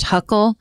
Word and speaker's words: Hukkle: It looks like it's pronounced Huckle, H-U-K-K-L-E, and the 2005 documentary The Angel Makers.